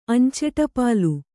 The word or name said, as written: ಅಂಚೆಟಪಾಲು